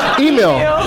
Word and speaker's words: Emil: Emil is spelled E-M-I-L. – Emil? – Emil. Emil. It's like emu, but with an I and an L. – Emil. – Emil. Virginia Hay. Hay is spelled H-E-Y.